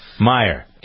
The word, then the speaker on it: Meyer: Meyer.